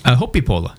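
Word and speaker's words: Hoppípolla: Hoppipola.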